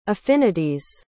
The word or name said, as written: affinity's